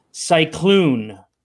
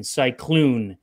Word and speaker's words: cycloon: Cyclone, and it's spelled C Y C L O O N. Cyclone.